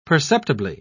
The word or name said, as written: perceptibly